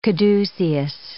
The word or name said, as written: caduceus